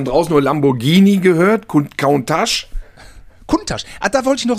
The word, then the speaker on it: Countach: Und er wird ganz schlicht und ergreifend Kuntasch ausgesprochen. Wie die Menschen da vor Ort sprechen, wie die Mundart, das ist die korrekte Aussprache des Lamborghini.